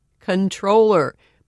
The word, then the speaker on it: comptroller: The dictionary says the preferred pronunciation is controller, but not using the preferred pronunciation seems to be a habit at the OCC.